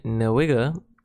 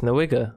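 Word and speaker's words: Nwigwe: We got Toby Nwiga, Heat Rock. Uh-huh. Huh? Did I say it wrong? – Absolutely. – It's Nwiga.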